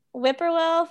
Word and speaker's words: Whippoorwill: Is that how you say it? – You got it. Yeah, okay. That's it, Kurt. Yeah. Whippoorwill Farm Day Camp.